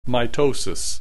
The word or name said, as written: mitosis